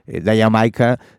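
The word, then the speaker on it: D'yer Mak'er: El título es incluso es un guiño a ese ritmo, porque Dayer Maker se pronuncia de forma muy parecida a Jamaica.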